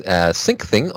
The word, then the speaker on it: Syncthing: Next thing is SyncThing on FreeBSD.